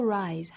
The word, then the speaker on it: rise: 单词尾部的 se 读成浊音的 z， because 或 because tomorrow the sun will rise，还是会升起的。 rise 这个词尾部的 se 也是读浊音 rise，我们要区分开这个词和另外一个 raise raise，那个词是双元音的 a，这个词是双元音 i， rise 和 raise。